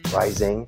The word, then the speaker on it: Reising: Last name is spelled R-E-I-S-I-N-G, Rising.